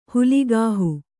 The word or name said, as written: ಹೊಲಗಾಹಿ